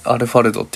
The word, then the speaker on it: ALFRD: 大文字で ALFRD でアルファルドって読む